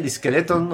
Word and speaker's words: Diskeletton: No sé si está bien Diskeleton o cómo lo pronuncio. – Sí, está perfecto.